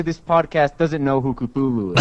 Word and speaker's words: Cthulhu: Oh, Call of Cthulhu.